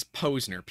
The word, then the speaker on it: Posner: I'd say it's like the rapper, like the bad rapper, Mike Posner, no relation.